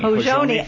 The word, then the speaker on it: Hozhoni: We have Hozhoni Foundation. – Hozhoni Foundation. – I knew I was pronouncing that wrong.